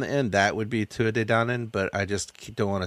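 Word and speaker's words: Tuatha Dé Danann: It's Tua De Danann. – What is it? – Tua De Danann. – Oh, you looked up how to pronounce stuff. – Yeah, or Tuatha De Danann.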